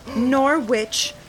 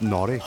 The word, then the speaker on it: Norwich: It's from Norwich Puppet Theater. – It's pronounced Norwich.